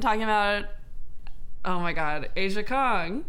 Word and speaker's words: Aja Kong: And she is a Japanese professional wrestler, better known as Aja Kong.